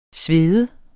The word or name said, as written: svede